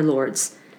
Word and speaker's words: Lourdes: Lourdes, France. Is that how you say it? – Lourdes? Lourdes? Yeah, Lourdes. Just Lourdes. Fine.